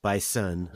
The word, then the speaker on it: Bison: Like if you're the Bison and you're at the top of, excuse me, Bison, I'll pronounce it with the S.